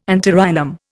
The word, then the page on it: antirrhinum: Indian